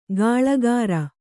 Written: ಗಳೆಕಾರ

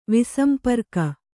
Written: ವಿಸಂಪರ್ಕ

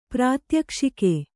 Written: ಪ್ರಾತ್ಯಕ್ಷಿಕ

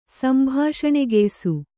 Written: ಸಂಭಾಷಣೆಗೆಯ್ಸು

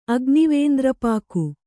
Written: ಅಗ್ನಿವೇಂದ್ರಪಾಕು

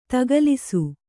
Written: ದಾಖಲಿಸು

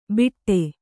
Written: ಬಿಟ್ಟೆ

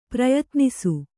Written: ಪ್ರಯತ್ನಿಸು

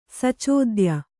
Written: ಸಚೋದ್ಯ

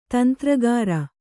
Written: ತಂತ್ರಗಾರ

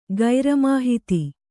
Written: ಗೈರಮಾಹಿತಿ

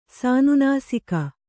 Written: ಸಾನುನಾಸಿಕ

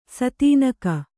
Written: ಸ್ಥಾನಿಕ

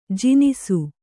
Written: ಜನಿಸು

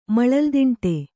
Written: ಮಳಲ್ದಿಂಟೆ

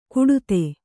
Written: ಕಡೆತ

